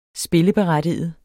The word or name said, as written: spilleberettiget